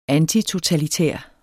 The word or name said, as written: antitotalitær